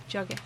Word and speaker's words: Drogheda: Drogheda. Spell that. – D-R-O-G-H-E-D-A.